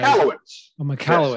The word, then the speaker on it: Michalowicz: Michael Witz, I don't know if I'm pronouncing that right. – Michalowicz. – Yes. Thank you. The wrong emphasis on the incorrect syllable, but what is the Profit First model?